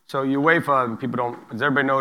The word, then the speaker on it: UEFA: I said it right for once. UEFA. – There you go.